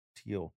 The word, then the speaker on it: Thiel: Not Peter Thiel. Doesn't he have an H in his name? – Yeah, but you don't pronounce it.